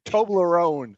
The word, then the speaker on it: Toblerone: Toblerone or Toblerone? Are you Toblerone. Get what? That Toblerone. No, it's Toblerone.